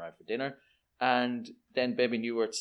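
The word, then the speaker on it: Bebe Neuwirth: I can never pronounce it. It's Baby Newirth or Newirth. – Baby Newirth, yeah.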